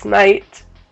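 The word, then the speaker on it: knight: But she's like, she made the reference where he pronounces it Knigget and it's night.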